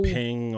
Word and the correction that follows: Bing: You didn't even get the name right. It's Bing. Bing.